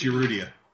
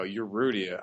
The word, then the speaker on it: Urrutia: He's kind of a strange guy because, and I probably got that name wrong. – Yes, Urrutia. – There we go, Urrutia. I got a problem with like the emphasis on the wrong syllable.